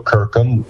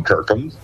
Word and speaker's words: Kirkham: Oh, Alan Joe Kirkham, and that's spelled with a K. And then there's just Alan Kirkham, and my last name's spelled K-I-R-K-H-A-M, and you can leave messages there if you want.